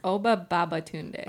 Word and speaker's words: Oba Babatunde: Wait, how do you say his name? – It's Oba Babatunde. – Oba Babatunde. – Okay, that's what I thought.